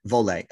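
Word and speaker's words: Voile: Is it Viole straps? Is that how you say it? – Vole.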